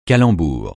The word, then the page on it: calembour: francais